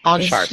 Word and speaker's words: Anshar: Anshar. Sounds like OnStar, but with an H in it.